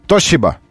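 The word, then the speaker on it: Toshiba: Но буквы «Ш» действительно нет, но есть некое подобие «С» и «Ш». Такое «С». Вот так. «С». «Тосиба». «Сиба».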